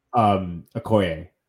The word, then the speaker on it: Okoye: How do you say her name? – Okoye. She's Okoye, actually the first Dormilaje we ever see on screen, though, in Civil War.